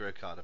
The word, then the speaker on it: Okada: I know I've pronounced that completely wrong, and I did that on purpose, especially with his surname, but it sounded cool, so we'll go with that. It's Okada.